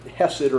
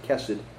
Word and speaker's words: hesed: It is a translation of a Hebrew word, hesed, or you'll sometimes hear people pronounce it kesed.